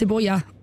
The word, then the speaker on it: cebolla: Is it Cebola? – It's Cebolla, but then... – Cebolla? – Yeah. – Well, Cebolla, Cebolla. – That's why I missed it. – If I'd said to you, Stephen, Cebolla, what is that? – You have to say onions.